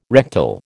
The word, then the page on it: rectal: American English